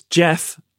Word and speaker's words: Jeff: So, and also, if I say my full name, if I just say Jeff, they almost invariably hear it as Jess. – Oh, really? – Yeah, and then I don't want to correct them. But if I say, yeah, it's Jeff Lloyd, something about the running the double F into the double L, because I do pronounce all the consonants.